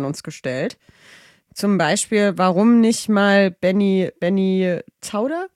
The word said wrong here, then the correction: Zander: als Gast? Zander? – Zander. – Zander? – Zander. – Okay, ihr merkt, ich habe den Namen schon mal falsch ausgesprochen.